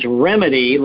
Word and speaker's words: Rimidi: And for anyone listening who has not caught the fact that the name of the company is Rimidi, like Rimidi to the solution, the remedy to the problem, it's spelled R-I-M, R-I-M-I-D-I.